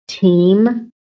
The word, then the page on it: TEAM: American English